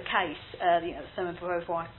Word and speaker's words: Beauvoir: You had women like Simone Beauvoir, who wrote the Beauvoir, I think it's pronounced, who wrote The Second Sex.